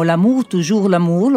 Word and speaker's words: L'amour, toujours l'amour: E parlando di vecchi ricordi, adesso ci ascoltiamo L'amour, toujours l'amour, l'ho detto bene, che è uno dei brani cantati all'interno del documentario.